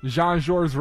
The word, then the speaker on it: Jean-Georges: Jean-Georges Restaurant in Four Seasons, is that how you pronounce it? – Yeah, exactly.